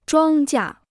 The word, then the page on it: 庄稼: zhuāng jia